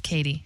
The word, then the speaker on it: Cady: Caddy Heron. It's pronounced Katie. – Yeah. C-A-D-Y, right? – That's right.